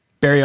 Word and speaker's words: Barrie: On September 24th, Barrie, is that how you say it? – Barrie, Ontario.